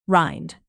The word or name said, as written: rind